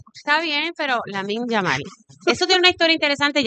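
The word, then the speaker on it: Lamine Yamal: Lamin Yamal.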